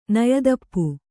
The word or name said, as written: ನಯದಪ್ಪು